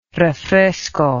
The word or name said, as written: refresco